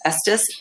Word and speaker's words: Estes: We have a Andy Warhol, Richard Estes. Is that how you say it? – Estes.